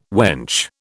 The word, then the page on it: wench: British English